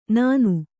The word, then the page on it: ನಾನು: Kannada